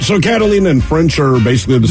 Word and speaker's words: Catalina: It's Catalina, right? Oh, am I pronouncing that wrong? I hope not. – No. – Yeah. – No, you're pronouncing it right. – That sounds familiar. I do remember Catalina.